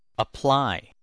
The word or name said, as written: apply